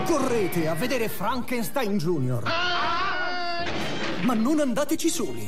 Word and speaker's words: Frankenstein: Con Gene Wilder nei panni del dottor Frankenstein. – Si legge Frankenstein.